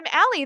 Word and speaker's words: Allie: I spell it A L L I E.